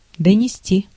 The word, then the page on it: communiquer: France; /kɔ.my.ni.ke/